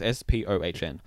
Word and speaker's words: Spohn: I don't know how to say his last name. It's Spohn. – That sounds right.